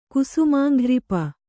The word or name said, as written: ಕುಸುಮಾಂಘ್ರಿಪ